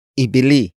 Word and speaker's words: ibili: The first word is ibili.